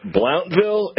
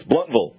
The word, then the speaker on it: Blountville: keep pronouncing it Blountville, and it's not. First off, it's not Ville, it's Vol, and Blount is not Blount, it's Blunt, so it's Bluntville."